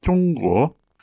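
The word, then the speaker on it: zhongguo (中国): And the Chinese name for China in Mandarin is pronounced zhongguo. Zhong, as we just mentioned, and the guo means country.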